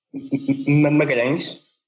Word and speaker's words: Magalhães: So, hi guys, my name is David Magalhães. It's the Portuguese, more or less, common name that you have a different translation.